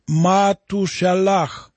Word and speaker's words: Матушалах: А имя Сефита Мафусала, сына Еноха, как Матушалах, отсылающий, прогоняющий смерть.